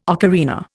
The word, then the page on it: ocarina: Indian